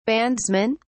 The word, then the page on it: bandsmen: British English